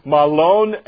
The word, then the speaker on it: Mahlon: They were there for 10 years, or Naomi was there for 10 years, and after 10 years, both of her sons, Mahlon and Chilion, is how you actually pronounce those names in the Hebrew, they passed away in the land of Moab.